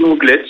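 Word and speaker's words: Anglet: Anglais ou Anglette? – Bah ici on dit Anglette.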